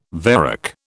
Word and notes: varec: British English

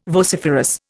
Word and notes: vociferous: Indian

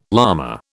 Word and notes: lama: British English

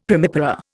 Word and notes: primipara: Indian

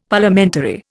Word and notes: Parliamentary: Indian

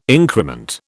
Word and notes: increment: British English

